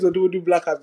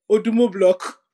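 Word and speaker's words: Odumodublvck: But I say his name is Odumodu Black. I'm screaming. – Yeah? – Do you know how I've been reading that guy's name all this while? – What? – Odumo Block.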